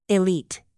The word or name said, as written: elite